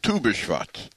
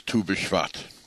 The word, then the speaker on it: Tu B'Shvat: The second of Shvat, I jokingly say, and I think I got it from my father, is Tu B'Shvat. Two, the number two, B'Shvat and Shvat, as opposed to two, made up of the letters Tet Vav, which is pronounced two, and is the 15th of the month, which is Rosh Hashanah La'ilanot, also known as Tu B'Shvat.